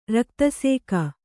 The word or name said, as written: ರಕ್ತಸೇಕ